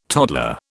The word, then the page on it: toddler: British English